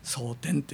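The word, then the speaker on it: 早天: まあ、ですから、まあ、ほとんどの教会では朝の礼拝っていうかそういう蒼天って言ってますが、早い天と書いて蒼天と言ってますが、これは非常に前向きなイメージとして大切だと思いますね。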